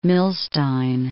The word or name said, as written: Milstein